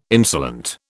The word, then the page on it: insolent: British English